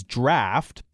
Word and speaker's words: draught: It's spelled D-R-A-U-G-H-T. I've spent enough time at the pub to know that this is draft, but what I don't know is how in the world we ended up pronouncing it draft instead of just draught.